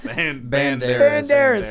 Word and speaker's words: Banderas: You got Anthony Barderas. How do you say Bardingle? – Banderas. – Holy shit. – Bardingle. – Banderas. – Did I say Banderas? Banderas. Antonio Banderas.